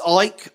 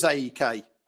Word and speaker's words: AEK: It's Ike. I know that's how you say it, John. I always thought it was AEK. But apparently it's Ike.